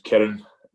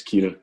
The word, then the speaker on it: Kiran: So it's actually spelled K-I-R-A-N and there's no E. So in India, it would be pronounced Kieran, but in Glasgow, it's Kieran.